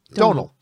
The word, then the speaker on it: Domhnall: And he said, okay, you know the word tonal? Yeah. Just think of that with a D. Oh, Domhnall.